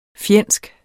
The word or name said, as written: fjendsk